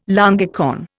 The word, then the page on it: longicorn: Indian